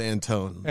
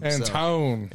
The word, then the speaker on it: Antoine: Because I thought it was Antoine, but then I guess there's an I in there, but apparently it's not pronounced. So it's Antone. – Antone.